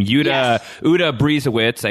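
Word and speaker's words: Uta Briesewitz: Directed by, I'm going to botch her name, Uta Brisewitz, I believe is how you pronounce her name.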